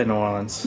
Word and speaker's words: New Orleans: I say New Orleans.